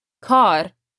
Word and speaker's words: kar: Watch the word kar. It's a long A. Kar.